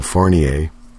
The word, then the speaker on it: Fournier: It's Michael Fournier, of course. Kind of a French thing.